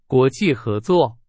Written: 合作

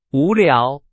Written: 无聊

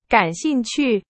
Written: 感兴趣

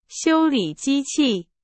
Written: 修理

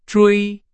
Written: 追

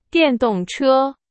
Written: 电动车

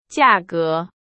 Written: 价格

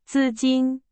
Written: 资金

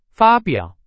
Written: 发表

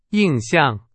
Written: 印象

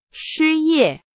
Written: 失业

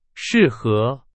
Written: 适合

